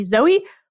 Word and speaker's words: Zooey: And I named them Franny and Zooey after the Salinger book. And I know I'm going to get emails about the pronunciation that it should actually be Zoe. Look, it's debatable, but we called them Zoo.